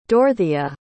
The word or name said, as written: dorthea